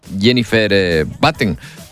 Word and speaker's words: Jennifer Batten: que se llama Jennifer Batten. Lo he pronunciado bien, director del Festival Mar Abierto. ¿Qué tal? Buenos días. – Hola, muy buenos días. Perfectamente, tu inglés está perfecto.